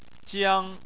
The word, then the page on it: jiang1: chinese